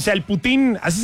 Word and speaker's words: poutine: Se escribe poutine. – Ajá, ahí está el poutine, uh-huh, poutine, pero se pronuncia putín.